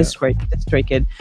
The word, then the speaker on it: DistroKid: DistroKid.